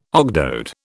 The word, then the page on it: ogdoad: British English